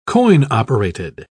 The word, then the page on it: coin-operated: British English